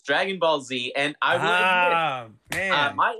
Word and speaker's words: Dragon Ball Z: This is called Dragon Ball Z? – Yeah. – I've been saying this wrong. I just thought it was Dragon Balls.